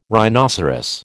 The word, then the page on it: rhinoceros: American English